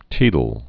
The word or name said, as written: teedle board